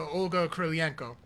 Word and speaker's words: Olga Kurylenko: You know, big ups to Olga Kurylenko.